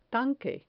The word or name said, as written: tanque